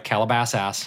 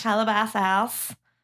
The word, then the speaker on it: Calabasas: Say it right. Calabasas. – Calabasas.